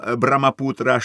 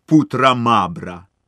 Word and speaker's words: Брамапутра: Слово «Брамапутра», щоправда, щодо вимови трудне, але їхавши на пароплаві, ви частіше його самі собі проказуйте, щоб навчитися його вимовляти.